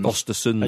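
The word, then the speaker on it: Östersund: Of course, names linked thus far: Frank de Boer, Yapstam, Chris Coleman, Swedish side Osterund's manager, Graham Potter. – Ostersons. And you're a host of On the Continent, so you knew the correct way to pronounce that.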